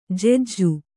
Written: ಜಜ್ಜು

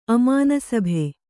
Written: ಅಮಾನಸಭೆ

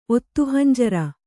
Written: ಒತ್ತುಹಂಜರ

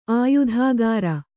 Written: ಆಯತಕರ

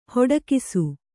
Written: ಹಿಡಿಕಿಸು

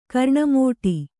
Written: ಕರ್ಣಮೋಟಿ

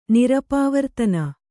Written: ನಿರಪವರ್ತನ

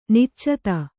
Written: ನಿಚ್ಚೈದೆ

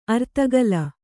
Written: ಅರ್ಧಗೋಲ

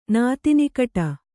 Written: ನಾತಿನಿಕಟ

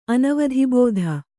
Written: ಅನವಧಿಬೋಧ

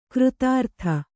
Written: ಕೃತಾರ್ಥ

